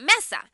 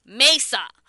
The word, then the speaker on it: Mesa: So we all know that Mesa is the third largest city in Arizona, and you're supposed to pronounce it Mesa, but the white natives call it Mesa.